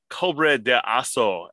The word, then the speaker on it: Cobra de Aço: I'm going to go to the Brazilian market, and I'm picking Cobra de Aço as my next choice. – How is that spelled? – Cobra, D-E-A, and it's the soft C-O, but A-C-O.